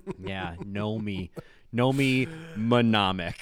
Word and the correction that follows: mnemonic: Know me, mnemonic. Yeah, know me, mnemonic.